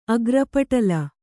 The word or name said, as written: ಅಗ್ರಪಟಲ